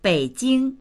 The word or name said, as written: bei3jing1